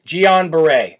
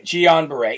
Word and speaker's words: Guillain-Barré: Guillain-Barré. Okay, that's how you say it, Guillain-Barré.